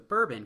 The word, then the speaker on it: bourbon: Because the staff there in the sweetest way possible responds to me, "Oh no, it's not bourbon coffee. I wish it had bourbon in it. It's bourbon."